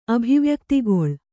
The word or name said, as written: ಅಭಿವ್ಯಕ್ತಿಗೊಳ್